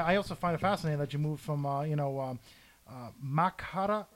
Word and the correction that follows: Mankato: how do you pronounce that? – Mankato? – Makata. I can't read my own handwriting. Mankato, Minnesota.